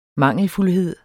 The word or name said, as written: mangelfuldhed